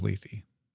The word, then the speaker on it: Lethe: Coyote Brown says Lath. – Lath. Well, the way they pronounce it in the audiobook is Lethe.